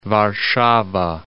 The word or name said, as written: Warszawa